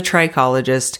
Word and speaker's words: trichologist: I was saying it wrong. It's a trichologist who specializes in holistic scalp health.